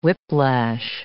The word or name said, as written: whiplash